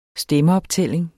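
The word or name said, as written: stemmeoptælling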